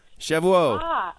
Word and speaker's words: Shavuot: How do you say that Shavuot.